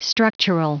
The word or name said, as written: structural